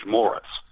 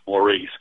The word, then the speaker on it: Maurice: Okay, and he used the American pronunciation, George Morris. – Oh, yeah. – And that's something I kind of expected. People nowadays would look at that and say, Maurice. But George Morris Thompson was my grandfather.